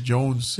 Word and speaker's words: Jonze: How would you say that? Jonze. – Jonze. Jones.